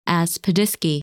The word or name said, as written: Aspidiske